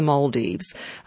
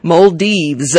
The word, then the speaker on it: Maldives: I know we all pronounce this different ways, but I did go onto Google and check, and it's Mall, as in the Hunter Street Mall, and Deves, I don't know, D-E-E-V-Z, if you like. Maldives, and I guess they're all Maldivians.